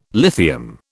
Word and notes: lithium: British English

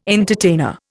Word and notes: entertainer: Indian

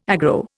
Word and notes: aggro: Indian

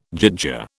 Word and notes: gyttja: British English

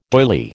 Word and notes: doyley: American English

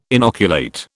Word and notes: inoculate: British English

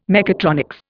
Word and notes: mechatronics: Indian

